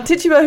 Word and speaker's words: Tituba: Tituba, who is from Barbados.